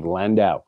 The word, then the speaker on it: Landau: Please welcome Mr. Dave Landau.